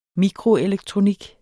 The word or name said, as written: mikroelektronik